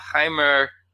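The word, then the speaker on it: Jeimer: The one guy I have left with the Cubs, and I'm guessing this is how you say his first name. I haven't heard it said yet. Jaimer Candelario. – Sure. – I'm assuming it's the Spanish H sound.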